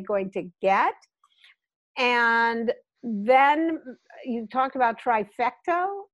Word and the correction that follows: trifecta: Did I say that right? Trifecto? I'm sorry, I didn't trifecta. – Know that. – Yeah. – Trifecta. All right.